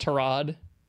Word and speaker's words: Tyrod: Tyrod was coming back. – Isn't it like pronounced Tarod? – Yeah.